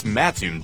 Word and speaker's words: Mattoon: Is it Mattoon or Mattoon? – It's Mattoon, dummy.